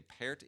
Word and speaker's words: Pärt: When I say Pärt, it is spelled like part, P-A-R-T, with two dots over the A.